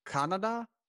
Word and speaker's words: Kannada: But for Karnataka, in the books, it says their main language, at least, is pronounced Kannada. – Yes, exactly. Oh, you got it spot Okay. – on.